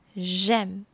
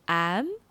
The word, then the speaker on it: j'aime: Troisièmement, j'aime, j'aime。这个呢，可以用来表达兴趣嗜好，要注意发音哦。有些人可能会被拼字影响，说成 am 或是 Amy，要改正过来就要多说几次，让正确的声音成为你的反射动作。 j'aime, j'aime, j'aime, j'aime, j'aime, j'aime。